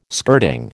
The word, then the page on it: skirting: American English